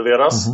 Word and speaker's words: Vedas: This is one of the Vedas, and it comes down to us orally, like all the Vedas. Mm-hmm. That's the right English pronunciation, right? Vedas, yes.